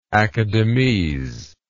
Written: academese

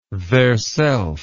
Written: theirself

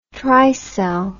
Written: Tricel

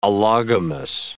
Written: allogamous

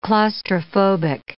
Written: claustrophobic

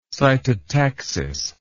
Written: cytotaxis

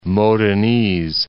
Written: Modenese

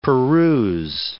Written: peruse

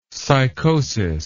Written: sycosis

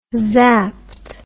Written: zapped

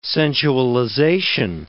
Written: sensualization

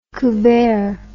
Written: couvert

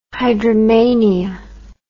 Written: hydromania

